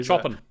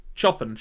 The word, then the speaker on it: Chopin: And then also there's just a very cool little bit of set dressing with the book The Awakening by Kate, I don't know how you pronounce the surname, it's spelled like Chopin, the Polish composer. – Chopin. – But it was Chopin, sure, Kate Chopin.